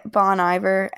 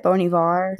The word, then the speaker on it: Bon Iver: It's called Creature Fear by Bon Iver, and that's how I say his name, but some people say it Bon Ivar.